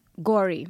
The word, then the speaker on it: Gauri: Gauri. – Gauri. – It's just like how you say sorry.